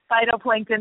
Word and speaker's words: phytoplankton: Did I say that correctly? Phytoplankton? Was that? – Yes. Phytoplankton, my favorite thing in the whole world.